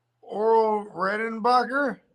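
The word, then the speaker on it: Orville Redenbacher: Popping like Oral Redenbacher.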